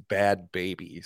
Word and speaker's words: Bhad Bhabie: It's spelled B-H-A-D-B-H-A-B-I-E. It's Bad Baby, spelled stupid.